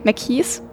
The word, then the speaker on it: McKee: McKee, McKees? Wie war das nochmal? Wie wird das mal ausgesprochen? – Haus? – Nee, McKee. McKee ist richtig, ne? – Ja.